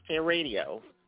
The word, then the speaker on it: hairadio: It's pronounced Hair Radio. It has just one R in the middle.